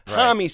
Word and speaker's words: Homme: If you go to Europe, apparently it's pronounced Josh Homme, and some people like just call him Homme. – Right. – Homme seems to be the one that we've settled on lately.